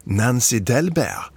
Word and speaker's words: Nancy Dahlberg: Nu ska vi ge plats åt en annan konstellation, en stråkkvartett av danska tonsättaren Nancy Dahlberg, eller Nancy Dahlberg om man försöker se på det danska uttalet.